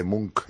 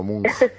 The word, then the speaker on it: Munch: Te agradezco además que me hayas revelado que en noruego se dice Munch y no Munch, porque yo a la germana estaba pronunciándolo mal.